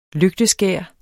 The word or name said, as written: lygteskær